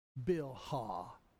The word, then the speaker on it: Bilhah: And we shall pronounce the word Bilhah. Bilhah. And then we shall pronounce the biblical name of Zilpah.